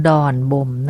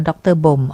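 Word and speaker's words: ดร.บ่ม: ดอนบ่มนะคะสะกดดอนเด็กรอเรือถ้าหากว่าไม่บอกมาก็จะอ่านว่าด็อกเตอร์บ่มอ๋อไม่ใช่ดอนบ่มค่ะ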